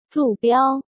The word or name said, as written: Zhu Biao